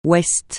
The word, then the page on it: ouest: francais